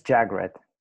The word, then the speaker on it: Jagreet: The I's always throw me off. I don't know how to pronounce the I's. So absolutely. You're almost there. It's pronounced as Jagreet. – Jagreet. Oh, yeah. So it's an A. See, the A, yeah. I didn't think about that, the A sound.